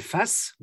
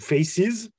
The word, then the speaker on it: F.A.C.E.S: Et euh, j'ai découvert un jeu de rôle français qui s'appelle Face ou faces si tu le prononces en anglais, donc euh, face avec un point entre chaque lettre, qui se veut un peu euh, comme euh, ce que Savage World aurait été si ça avait été vraiment bien écrit.